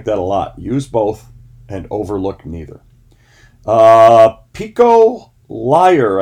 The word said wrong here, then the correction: Iyer: I believe, Iyer, I believe is how you say this person's name.